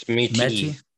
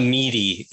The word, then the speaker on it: Métis: It's Métis.